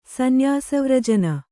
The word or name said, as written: ಸನ್ಯಾಸವ್ರಜನ